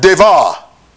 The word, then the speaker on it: dabar: That word in the Hebrew, it is spelled D-A-B-A-R. D-A-B-A-R. It is pronounced devar. Devar. But it's spelled D-A-B-A-R. That's the word in the Hebrew. It is pronounced devar. D-A-V-A. Devar in the Hebrew.